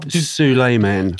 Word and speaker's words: Souleiman: Eugene Solomon, Souleiman, Solomon, Souleiman. Do you ever come up? – Souleiman.